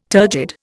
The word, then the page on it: turgid: Indian